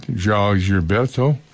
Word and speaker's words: João Gilberto: And earlier on, we heard let's get his name right, João Gilberto, and his album simply called Brazil.